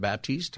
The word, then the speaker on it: Baptist: You're listening to the Pastor Scott Show, and is it Erik Baptist or is it Baptiste? How do I say it? Am I saying it right? – It's Baptist, just like the church.